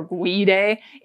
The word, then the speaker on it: guide: This word may look like it's spelled guide or guide. It's not, it's guide.